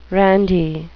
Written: randy

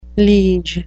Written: liege

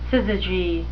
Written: syzygy

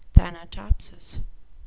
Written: thanatopsis

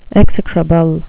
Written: execrable

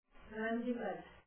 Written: gundygut

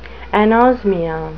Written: anosmia

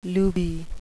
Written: looby

